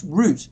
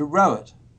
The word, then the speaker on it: Roet: My father was Dutch, and the name Rowett, R-O-E-T, yes, is pronounced root in Dutch. – Ah. – And when we moved to Australia, the word root in Australia means sexual intercourse, as does many other words in the Australian country. So my mother changed the pronunciation to Rowett.